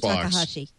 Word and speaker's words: Takahashi: Dean Hakatashi or something. – Akamovich. – This is the former Xbox guy who kind of writes about video gaming now. Said that Xbox. – Dean Takahashi. – Takahashi, thank you.